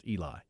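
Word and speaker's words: Eliah: But it's spelled E-I-L-A-H, but it's just pronounced Eli. So it looks like Aliyah.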